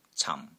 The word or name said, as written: CAM4